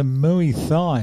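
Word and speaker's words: Muay Thai: She has a mooey thigh. Is that what you're saying? – That's exactly how you say it.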